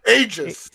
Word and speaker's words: Aegis: Aegis. – Yeah, like pretend it's a J and then you're all okay. Good. – Aegis. So it has Aegis